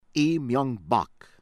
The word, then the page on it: Lee Myung-Bak: American English; South Korea